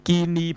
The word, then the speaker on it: guinea: 咁啊，嗱你招聘有啲人讀就叫做讀 guinea pig 啦，guinea pig 啊，你揾我嚟做 guinea pig 啫，就係錯嘅，因為個 nea 咧，原來咧嗰個 a 字係唔發音嘅，基本上，所以咧你唔好讀 nea。其實是 guinea pig 啊，guinea pig 係一個音，唔係兩個音。